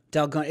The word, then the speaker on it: dalgona: I don't know how to it's spelled D-A-L-G-O-N-A, dalgona. Mm-hmm. That's how I say it.